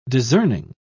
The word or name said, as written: discerning